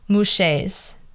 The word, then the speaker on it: muxe: So my third and final one is Muxes. They're from Mexico, and that's like basically their third gender, Muxes. So it's spelled M-U-X-E, but they pronounce it Muxe.